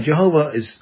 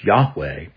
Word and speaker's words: Yahweh: Now, Jehovah is the way that people sought to pronounce the name of God, although we know now that the more correct way of pronouncing his name is Yahweh.